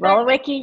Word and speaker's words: Wolowiecki: Wolowiecki, yes, that's exactly right.